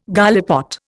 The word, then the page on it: gallipot: Indian